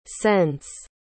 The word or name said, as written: sence